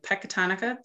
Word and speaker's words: Pecatonica: Dr. Underley is currently the superintendent, and I can never pronounce this word, Pecatonica. Did I get it right? – Yes, nice.